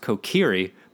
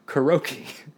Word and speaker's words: Kokiri: It's actually pronounced Kokiri, but I pronounced it Kuroki.